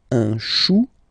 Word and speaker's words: chou: Then, un chou, okay, remember, when you combine this C and H, you get the sound sh, sh, sh.